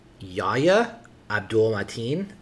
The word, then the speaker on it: Yahya Abdul-Mateen: It's Yahya Abdul-Mateen is our lead, Anthony McCoy / spoilers, the Candyman.